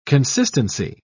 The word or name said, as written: consistency